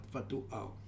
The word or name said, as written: Fatu Ao